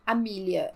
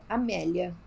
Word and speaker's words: Amelia: A Amélia, vou chamar ela, eu não vou falar Amília, Amélia.